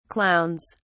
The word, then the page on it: clowns: British English